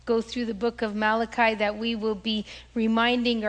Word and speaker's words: Malachi: Some people will call him Malachi, but I'll just give you the proper enunciation. He's not Malachi, although it's C-H. That C-H in Italian is pronounced ka, so Malachi.